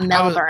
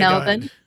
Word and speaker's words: Melbourne: And that's how they say it too. It's not Melbourne. – I was about to comment. Thank you for pronouncing it correctly. Anyway, Melbourne.